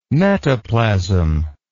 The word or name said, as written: metaplasm